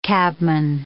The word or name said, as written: cabman